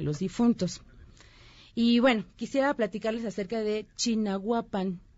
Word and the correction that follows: Chignahuapan: No sé si han escuchado, no sé cómo se pronuncia, Chignahuapan. Chignahuapan. Chignahuapan.